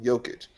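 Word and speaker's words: Jokić: See, they always pronounce it wrong. Jokic. Got to silence up that J.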